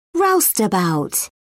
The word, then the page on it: roustabout: British English